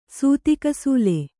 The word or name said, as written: ಸೂತಿಕಸೂಲೆ